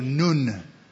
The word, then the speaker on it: Nun: We pronounce it Nun, but it's in Hebrew, Nun.